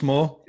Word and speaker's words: Maw: I presume that's how you pronounce it. I haven't just made a hash that, have I? – T- yeah, Thomas Maw, yeah, M A W.